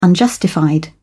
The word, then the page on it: unjustified: British English